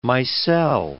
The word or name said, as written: micelle